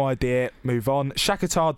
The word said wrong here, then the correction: Shakhtar: You managed to mispronounce both of those teams. That was impressive. – Shakhtar. Oh, sorry. Shakhtar Donetsk. – There's an extra syllable coming in there.